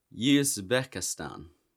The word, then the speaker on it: Uzbekistan: Uzbekistan. That's how you say it.